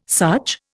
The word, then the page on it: such: Indian